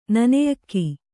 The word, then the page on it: ನೆನೆಯಕ್ಕಿ: neneyakki